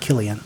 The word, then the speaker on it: Cillian: Oh, before we continue, I didn't know this for a while, but apparently it's pronounced Killian. – Is it really? – Yeah, it's pronounced Killian. Killian Murphy.